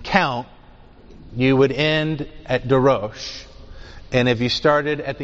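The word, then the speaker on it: darosh (דרש): The word getting translated "carefully search" and so forth is the repetition of the verb darash, which would be pronounced darosh, or darash darosh. The second one should say darosh.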